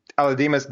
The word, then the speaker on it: Aledmys: How do you pronounce it? Aledmys Diaz. Yeah, I, I guess I, that's what, yeah, yeah.